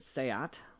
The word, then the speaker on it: SEAT: But in Europe, they have Skoda and Seat, which was a... it's spelled seat, but it's pronounced Seat, I assure you.